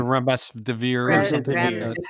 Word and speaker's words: remdesivir: One of the things that it's called, I don't know how to pronounce it. It's the R medicine. – The remdesivir. – Remdesivir. – Right.